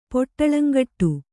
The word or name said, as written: ಪೊಟ್ಟಳಂಗಟ್ಟು